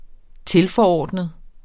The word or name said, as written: TILFORORDNET